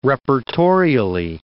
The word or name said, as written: reportorially